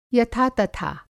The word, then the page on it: यथातथा: Hindi